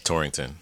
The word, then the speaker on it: Torrington: Torrington.